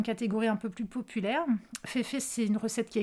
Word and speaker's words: Fefe: Fefe, ça s'écrit F-E-F-E, e, pour Fée en français. Féfé, c'est une marque qui a été lancée par euh, un, groupe qui s'appelle le Syndicat.